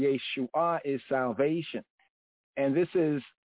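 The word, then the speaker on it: Yeshua (ישועה): In the Hebrew, we have Yeshua, Yeshua. Emphasize that H, the hey sound at the end. Yeshua, Yeshua, Yeshua.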